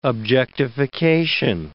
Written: objectification

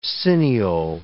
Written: cineole

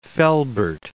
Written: Velbert

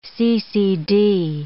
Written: CCD

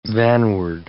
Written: vanward

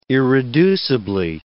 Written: irreducibly

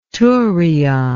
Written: turion

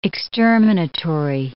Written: exterminatory

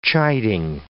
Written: chiding

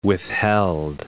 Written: withheld

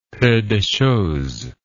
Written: peu de chose

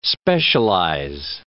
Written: specialize

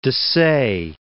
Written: Dessaix

